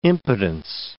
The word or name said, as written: impotence